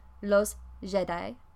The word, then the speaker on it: Jedi: Jedi, los Jedi, los Jedi. It's a bit of a weird word. The pronunciation doesn't quite match the spelling like it normally would, but I think that's just because it's taken from English, and in English it's a made-up word.